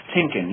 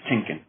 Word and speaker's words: Tienken: It's pronounced Tienken, yes, Chris Tienken. Perfect, Chris.